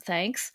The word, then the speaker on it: THKS: Well, my recommendation to everyone listening is to check out Hope's show on YouTube, THKS, the Hope King Show, pronounced thanks, the first Asian American talk show on YouTube.